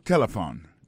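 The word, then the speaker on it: telephone: On a remarqué que l'accent tonique là tombé sous premier syllabe là. Si nous besoin de dire bien, c'est téléphone. Alors, ou prononcer ou appuyer plus sous premier syllabe là qui c'est T.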